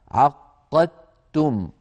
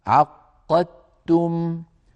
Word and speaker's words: عقدتم: فهنا ندغم الدال في التاء يعني نحول الدال إلى تاء وندغم التاء في التاء فيصير النطق بتاء مشددة هكذا عقدتم.